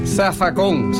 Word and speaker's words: zafacón: Zafacón, se escribe con Z, pero se dice con S en puertorriqueño.